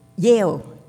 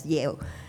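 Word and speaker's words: Jael: There was Barak, the general, who said, "I won't go unless you go with me," and also with Yael. It's J-A-E-L, but I think scholars pronounce it as Yael, the wife of Heber the Canaanite.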